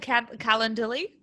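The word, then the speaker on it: Calendly: And like, even you were like, set up a calendarly, is how you say it.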